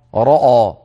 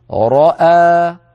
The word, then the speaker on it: رأى: فمن جملة ذلك أن الراء المفتوحة مفخمة لكن الهمزة لا، فلا نقول لا نقول رأى رأى، بل نقول رأى راء، فنفخم الراء ثم مباشرة بعد انتهاء صوت الراء يعود الفم إلى وضعه الطبيعي فننطق الهمزة مرققة، وكذلك الألف التي بعد الهمزة هكذا راء راء، هكذا نطق هذه الكلمة نطقا صحيحا.